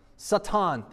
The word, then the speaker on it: Satan: Satan, that's how you pronounce it in Hebrew. You see it up on the screen and you're thinking, that's not how you pronounce it. You pronounce it Satan, right?